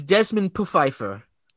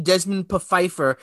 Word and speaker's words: Desmond Pfeiffer: It's Desmond Pfeiffer. – Oh, is it? – Yeah, they pronounce it Desmond Pfeiffer, and they say the P is not silent.